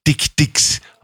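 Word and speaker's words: Dikdik: Neu eingezogen ist ein Pärchen Kirk Dick Dix, ausgesprochen so wie Dirk, und das sind afrikanische Zwergantilopen, gerade mal 4 bis 5 Kilo schwer.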